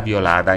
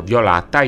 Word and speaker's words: violada: e questa è pasta violada in sardo. Qualcuno sta dicendo violata, io dico violata, vuol dire violentata, sì.